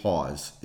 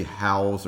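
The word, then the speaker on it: Hause: What I'm talking about is it's a guy named Dave Hawes. If you look at his name, it you're going to want to say Howes or something like that, but it's H-A-U-S-E. Just think, rhymes with cause.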